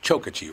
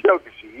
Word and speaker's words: Chokachi: Actually, it's pronounced Chokachi, right? – Chokachi. You got it.